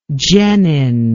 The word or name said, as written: genin